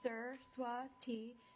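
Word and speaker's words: Saraswati: My name is Sir Swati Singh. My first name is pronounced Sir Swati, and my last name is Singh as in song.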